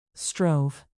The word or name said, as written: strove